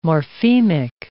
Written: morphemic

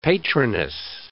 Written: patroness

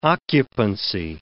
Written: occupancy

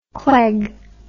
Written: cleg

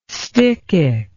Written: stichic

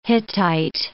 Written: Hittite

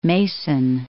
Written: Mason City

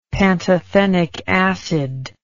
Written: pantothenic acid

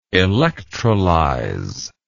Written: electrolyzation

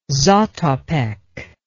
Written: zatopek

